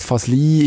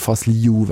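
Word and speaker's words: Fazliu: Und er hat uns gesagt, Fasli ist die schweizerdeutsche Aussprache eigentlich, so die akzeptierte, ist auch okay, wenn man es so sagt, weil es so im Schriftbild nachher sich orientiert. Fasliu wäre ganz korrekt.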